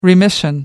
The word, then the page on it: remission: American English